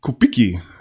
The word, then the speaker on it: Kubicki: Kubicki, habe ich jetzt gelernt, wird es ausgesprochen, nagelt gegen Merkel-Appell.